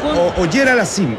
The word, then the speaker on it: Auger-Aliassime: Pues es, disculpe la pronunciación, Auger Aliassime. – Es Auger-Aliassime, el canadiense. Félix Auger-Aliassime.